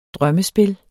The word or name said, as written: drømmespil